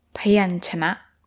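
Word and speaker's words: Payanchana (พยัญชนะ): Payanchana.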